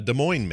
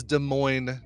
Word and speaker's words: Des Moines: Like, what is Des Moines known for? – Having a weird name that looks like it's pronounced Des Moines.